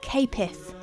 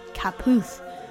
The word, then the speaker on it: Caputh: When we contacted him about using the song, he revealed that it should be pronounced Capith, not Caputh, which is how it's written.